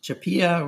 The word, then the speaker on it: CHAPEA: Yeah, CHAPEA.